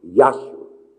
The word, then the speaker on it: Yahshua: The Hebrew word is Jehoshua, J-E-H-O-S-H-U-A. Jehoshua. The Aramaic word is Yahshua. That's the Aramaic word. It's pronounced Yahshua. The emphasis is on the Y-E, or the E is like an A.